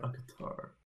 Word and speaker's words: Qatar: Qatar. – Okay, that's pretty close. That's pretty close. I said the car. – That car is not how I was supposed to pronounce that.